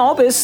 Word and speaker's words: Albus: I'm Sam Albus, the co-host and co-writer of the show.